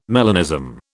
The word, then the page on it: melanism: British English